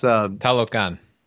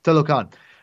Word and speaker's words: Talokan: How do you pronounce the city again? It's Talokan. – Talokan. I said it right earlier, I thought. Talokan.